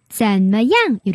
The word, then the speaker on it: 怎么样: 발음은 앞에 발음은 잔머 그리고 마지막에 양 빨리 하시면 怎么样? 이렇게 하시면 돼요.